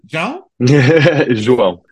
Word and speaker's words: João: But I've got, I'm going to butcher it. It's João? – João. It's impossible to pronounce.